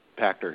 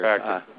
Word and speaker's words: Pachter: Oh, it rhymes with Schachter. It's Pachter here. – Pachter, yeah.